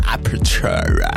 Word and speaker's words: Aperture: Harry Styles mit Aperture. Isabel, habe ich es richtig ausgesprochen? – Haargenau. – Wirklich? – Ja. – Aperture.